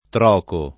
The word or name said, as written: troco